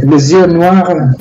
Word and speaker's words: Les Yeux Noirs: Y en esta yo voy a necesitar tu ayuda, porque vamos a escuchar Los Ojos Negros de Django Reinhardt, pero me gustaría que tú lo pudieras pronunciar en francés, porque para mí esto es un misterio. – Bueno, on va écouter Les Yeux Noirs de Django Reinhardt.